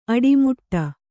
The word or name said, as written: ಅಡಿಮುಡಿ